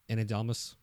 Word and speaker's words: Ana de Armas: Ana de Armas. Did I say that right? – Yeah.